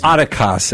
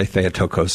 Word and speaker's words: Theotokos: Theotokos, as my Greek professor said. There's a fight over how you pronounce that, but most people say Theotokos, so I just go with what most people wrongly say. And say sometimes Theotokos. You have to accept the wrong pronunciation.